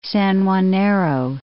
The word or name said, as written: San Juanero